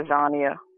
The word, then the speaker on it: Azania: Zolo Agana Anzina, I think is how you pronounce his name. What was that? – Zolo Azania. – Azania. Zolo Azania, who is being held captive in the Indiana State Prison.